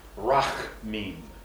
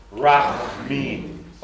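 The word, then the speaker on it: rachmim (רחמים): In English, R-A-H-A-M-I-M. A hard H and a small A after it. It's pronounced something like rachmim. Want to try it? Rachmim. Oh, come on. Rachmim. A good H. Pretend you're German.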